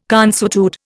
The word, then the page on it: consuetude: Indian